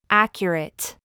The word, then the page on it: foot: American English